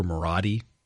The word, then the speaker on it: Marotti: Cooper Marotti, which really bothers me because it sounds like Rhodey to me, but that's how you pronounce his name apparently.